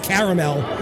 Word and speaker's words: Carmel: It's still pronounced caramel.